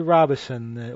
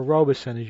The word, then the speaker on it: Robison: And Willard Robison, or Robison, as he would pronounce it both ways, in the Panda Troubadours, in our theme, Wake Up Chillin'.